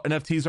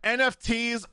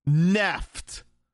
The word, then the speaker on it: NFT: Or maybe it's because The Verge tried to say that NFTs are pronounced neft.